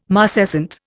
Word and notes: marcescent: Indian

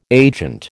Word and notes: agent: American English

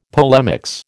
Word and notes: polemics: American English

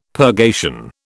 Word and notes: purgation: British English

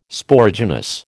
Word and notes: Sporogenous: American English

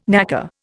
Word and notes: knacker: Indian

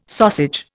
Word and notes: sausage: Indian

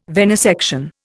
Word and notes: venesection: Indian

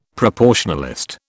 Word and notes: proportionalist: British English

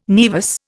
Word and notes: naevus: Indian

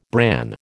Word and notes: bran: American English

